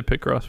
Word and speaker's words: Picross: Most people say Picross, but Right.